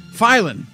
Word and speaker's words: Filion: I want to say Filion. It's Filon. I'm putting an extra syllable in there for you.